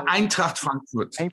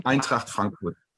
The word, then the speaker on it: Eintracht Frankfurt: I know you have a strong relationship with the club Eintracht Frankfurt. Not sure if I'm pronouncing it this way, the right way. – Is that Eintracht Frankfurt. Eintracht Frankfurt.